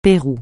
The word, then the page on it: Pérou: francais